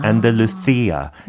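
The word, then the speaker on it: Andalucía: 그 SIA가 아니고 스페인어를 CIA 하고 약간 아. 안달루시아, 약간 아. 바셀로나 이런 식으로 해야 되는데 아.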